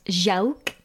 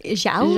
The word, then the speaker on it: Jacques: Yeah, we call him, yes, he's pronounced Jacques. – Jacques. – Jacques. Jacques. Almost like with an O.